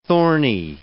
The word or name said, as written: thorny